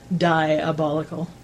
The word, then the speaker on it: Dyeabolical: And I should mention it's spelled D-Y-E-A-B-O-L-I-C-A-L. Diabolical.